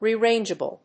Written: rearrangeable

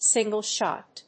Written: single-shot

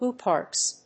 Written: ooparts

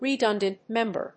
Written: redundant member

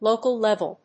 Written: local-level

